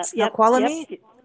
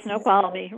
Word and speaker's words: Snoqualmie: Snoqualmie. And Snoqualmie, right.